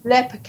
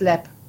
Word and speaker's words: Leap: Lep Castle, L-E-A-P.